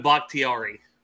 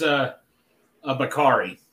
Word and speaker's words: Bakhtiari: David Bakhtiari. – Bakhtiari. – Okay, I thought it was a Bakari.